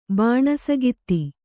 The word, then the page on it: ಬಾಣಸಗಿತ್ತಿ: bāṇagitti